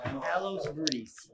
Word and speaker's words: Palos Verdes: My son and I doing the daf on vacation in Palo Verde. How do you pronounce it? California? – Palos Verdes. – No, V-E-D-E.